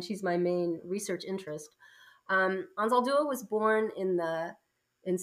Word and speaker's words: Anzaldúa: So you mentioned Gloria Anzaldúa. Am I saying that correctly? – It's a hard one. It's Anzaldúa.